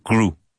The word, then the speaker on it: grew: I hear a lot of students saying something that sounds like grew, but it's one syllable, grew. It should rhyme with new, new.